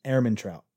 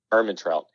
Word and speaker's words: Ehrmantraut: Ehrmantraut. – Ehrmantraut.